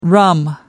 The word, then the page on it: rum: American English